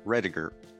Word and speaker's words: Rediger: I am very excited to bring on Jeffery Rediger.